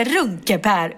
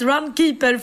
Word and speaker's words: Runkeeper: Hon tog fram sin mobil och visade och då insåg jag att Runkepär uttalas inte Runkepär utan Runkeeper.